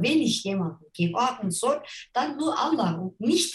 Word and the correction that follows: Allah: Jetzt bin ich gerade drauf gekommen, ich habe es falsch ausgesprochen. Sie betonen es auf dem, auf dem L, Allah. Allah. Allah.